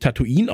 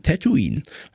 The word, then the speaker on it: Tatooine: So, ja, aber der Planet wird nicht Tatooine ausgesprochen, sondern Tatooine.